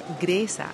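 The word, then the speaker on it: Griesa: Judge Thomas P. Grisey, or Gresa, as it's pronounced in Spanish.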